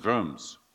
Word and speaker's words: Worms: Pronunciation in German is Worms.